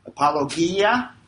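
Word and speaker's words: Apologia (ἀπολογία): apologia. Apologia, that's how you pronounce that.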